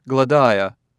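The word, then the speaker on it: Gladia: Her name is Gladiah." Gruer pronounced it in three syllables, accenting the second.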